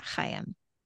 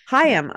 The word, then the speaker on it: Chaim: I think it's Chaim. – Chaim. – Yeah. – Which is nothing like Charlie. – No, but maybe just because of the CH. But I'm pretty sure they say it Chaim.